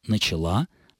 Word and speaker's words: начала: Хочу еще раз подчеркнуть, что единственно правильным будут следующие формы. «Начал», «начала», «начали». Все остальное является нелитературным и, в лучшем случае, просторечным.